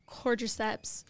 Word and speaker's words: cordyceps: Is if the whole point of the cordyceps, am I saying that right? – Cordyceps.